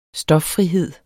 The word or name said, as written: stoffrihed